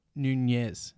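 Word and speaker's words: Núñez: Nunes is going to come. I don't know. I'm wondering what's happening because obviously Nunes has to. I'm getting stuck on Nunes because it's actually pronounced Nunez.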